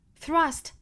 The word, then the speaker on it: thrust: 后面 then have high office, high 和 office 连读 high office, high your office 加一个小小的 ye 的发音 high office thrust upon thrust 这个单词的 th 要把舌头伸出来去摩擦上齿 thrust thrust 和后面的 upon 连读 thrust upon,